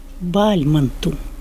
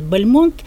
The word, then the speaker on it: Бальмонт: Хотя его чаще произносят Бальмонт, потому что завершил он свою жизнь в Париже, о нем говорили по-французски, и, конечно, Бальмонт, Бальмонт, но я читала специальную статью, где говорилось, что вообще-то он Бальмонт.